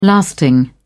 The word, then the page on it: lasting: British English